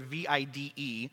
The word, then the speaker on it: vide: And then the second word, which the second half of the word, V I D E, is actually uh, pronounced vide in Latin, and it means to see.